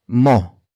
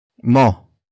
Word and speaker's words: mo: After that, we have mo. Mo.